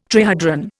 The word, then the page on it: trihedron: Indian